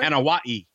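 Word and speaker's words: Anoa'i: And the late Rodney Anoa. Am I saying that right? – Anawai. – Anawai. That's Yokozuna. Anawai, yes.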